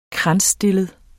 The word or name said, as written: kransstillet